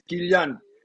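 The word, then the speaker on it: Kylian: Acabo de oír cómo se pronuncia Nosotros le decimos Kilian y es Kilian, Kilian Mbappé.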